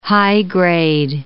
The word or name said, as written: high-grade